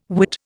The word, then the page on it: Whit: Indian